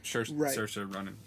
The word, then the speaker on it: Saoirse: Is that really how that's pronounced? – Yeah, only because the actress, Saoirse Ronan, yeah.